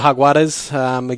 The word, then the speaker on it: Jaguares: Well, I think we expected the Lions to beat the Jaguares again. That's the correct pronunciation, I'm told, Christy.